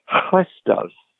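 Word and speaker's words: Christos (Χριστός): And Paul is the one who chooses the word the Greek word Christos, and here how I pronounce that. Again, it's like a clearing of your throat at the beginning of the word Christos. And as you say this word, again, there is the expulsion of breath.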